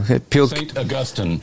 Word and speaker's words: St. Augustine: St. Augustine. – That's right, yeah. I just figured we'd see how you pronounce it. People get upset if you pronounce that one wrong.